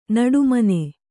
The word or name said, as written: ನಡುಮಣ್ಣು